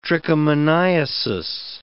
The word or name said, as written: trichomoniasis